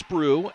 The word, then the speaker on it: Brugh: Reese Brew and her sister Addison. Addison's in the game. We'll see Reese at some point. Spell their last name B-R-U-G-H, but it's pronounced like it's B-R-E-W.